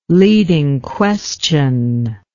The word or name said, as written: leading question